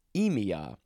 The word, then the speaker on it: -emia: So we have a suffix, EMIA, E-M-I-A. Yep, that's how you pronounce it.